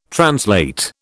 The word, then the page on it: translate: British English